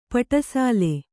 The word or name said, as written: ಪಟಸಾಲೆ